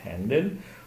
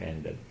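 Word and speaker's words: Händel: Tedesco, però visse gran parte della vita a Londra, e quindi qualche volta si ha la pronuncia tedesca Georg Friedrich Handel, o inglesizzata, diciamo così, anglizzata, George Friedrich Handel.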